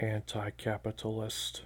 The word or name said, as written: anticapitalist